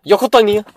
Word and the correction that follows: Yucatan: Yucatan? – Yucatan? – Yeah. I was so fucking close, dog, huh? Isn't it? – Yeah. – Yucatan, right? – Yo, dog, but these kids with their fucking...